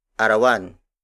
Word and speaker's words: arawan: For example, arawan, arawan, arawan.